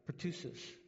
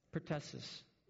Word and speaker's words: pertussis: Pertussis. What is it? Pertussis.